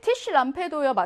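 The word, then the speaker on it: Tischlampe: 발음 티쉬 람페 합성 명사니까 강세는 앞에 있습니다.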